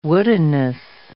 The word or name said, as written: woodenness